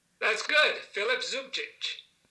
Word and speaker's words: Zubčić: Jimmy, it's at the end, it's also a CH sound. – Oh, it is. Okay. – Yeah, you know, this is apparently Croatian. That when you with that little accent on the last C, mm-hmm. turns it into a ch sound. – Okay. – So both the C's in Zubčić are sounded the ch.